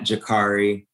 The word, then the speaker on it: Jacarri: It's at Jakari Moore, and it's spelled J-A-C-A-R-R-I, Moore, M-O-O-R-E.